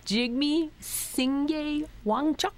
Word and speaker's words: Jigme Singye Wangchuck: Okay, in the book, you mentioned that in 1999, the king, and I'll try not to mispronounce his name, correct me, Jigme Singye Wangchuck.